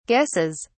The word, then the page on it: guesses: British English